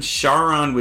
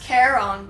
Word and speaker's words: Charon: The myth goes that the ferryman Charon would take the souls of the dead to Hades. – It's pronounced Charon, by the way.